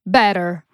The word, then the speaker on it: better: Better. – Better. – Better. – Okej, czyli tak jak mówisz, bliżej better, bliżej D niż T.